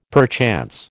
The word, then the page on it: perchance: American English